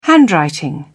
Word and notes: handwriting: British English

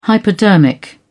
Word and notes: hypodermic: British English

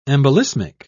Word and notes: embolismic: British English

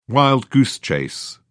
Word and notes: wild goose chase: British English

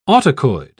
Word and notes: autacoid: British English

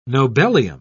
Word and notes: nobelium: British English

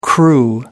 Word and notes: crew: American English

